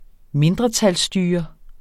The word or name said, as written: mindretalsstyre